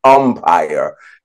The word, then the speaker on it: umpire: I said, I'm saying empire all week. I knew I was saying umpire. I was reading it, but I'm pronouncing it with an E. So I want to make sure I put special emphasis on saying umpire this week, right?